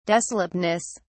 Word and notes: desolateness: British English

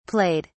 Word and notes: played: British English

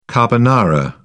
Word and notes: carbonara: British English

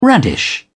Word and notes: radish: British English

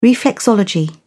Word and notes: reflexology: British English

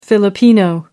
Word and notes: Filipino: American English

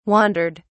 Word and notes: wandered: British English

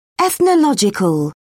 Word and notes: ethnological: British English

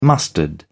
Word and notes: mustard: British English

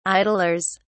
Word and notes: idlers: British English

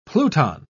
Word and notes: pluton: British English

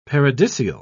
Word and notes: paradisial: British English

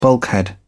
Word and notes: bulkhead: British English

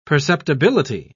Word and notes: perceptibility: British English

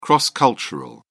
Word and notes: cross cultural: British English

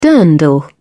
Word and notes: dirndl: British English

